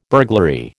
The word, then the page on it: burglary: American English